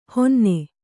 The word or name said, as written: ಹಿನ್ನೆ